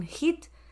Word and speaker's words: HIIT: voy a hacer un hit que se escribe H-I-I-T, que puedes buscar en internet eh, ejercicios sobre esto.